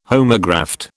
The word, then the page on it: homograft: British English